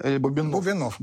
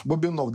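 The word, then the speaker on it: Бубеннов: И после всего этого, я так не делаю монтаж, позвонил, всем путаю ударение, Бубенов, да? – Бубенов. – Бубенов, да, писатель.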